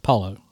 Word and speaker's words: Paolo: Paolo. – Okay, so a little bit more of an O than I did. Mine was more of a U.